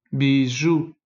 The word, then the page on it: bijoux: son u; ou